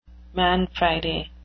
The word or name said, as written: man Friday